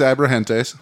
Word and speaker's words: Abrahantes: I didn't say it right. Go ahead and correct me. – Alex Abrahantes. – Yep.